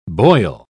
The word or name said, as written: boil